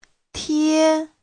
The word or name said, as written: tie1